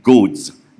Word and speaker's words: goads: Firstly, it's like goads, G-O-A-D-S. Just in case I pronounce this not well, and it sounds like goats. Can you G-O-A-T-S. Can you tell the difference? Right? There should be a difference.